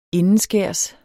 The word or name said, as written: indenskærs